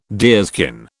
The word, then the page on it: deerskin: British English